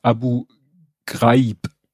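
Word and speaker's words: Abu Ghraib: Dann habe ich äh, Abu Grabi, das heißt, und äh, das das heißt Abu Graib.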